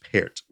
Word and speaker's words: Pärt: So if you also don't know the music of Arvo Pärt, I would definitely suggest going to look that up, P-A-R-T. It looks like part, but it's pronounced Pärt. All right, anyway, so the Western classical music industry, the American orchestral industry, celebrated some good news last week.